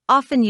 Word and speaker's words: often: For example, in formal English, the word often is pronounced with a silent T, whereas in informal English, the T is often pronounced.